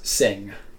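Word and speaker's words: Cing: So this was made by Sing. Am I saying that right, or is it King? – Yes, Sing.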